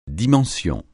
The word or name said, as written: dimension